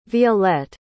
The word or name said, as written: violette